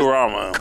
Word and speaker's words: Kurama: and Kamaru. Did I say it right? What was that dude? – Kurama. Okay. – I was close.